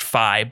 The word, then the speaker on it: Fi: And I know that it's technically pronounced Fi, but I don't think any of us care. We're just going to go with Fee because it sounds better.